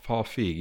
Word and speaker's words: Feig: Yeah, Paul Feig.